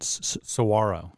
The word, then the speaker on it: Saguaro: Saguaro.